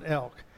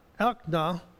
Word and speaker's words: Elkanah: No, I pronounce it Elk, but it's not Elk. It's Elkanah.